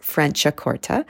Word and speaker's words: Franciacorta: I've heard people call it Franciacorta with some other sounds at the end that don't really make a lot of sense, but actually it's pronounced Franciacorta, and it is a sparkling wine that comes from Italy.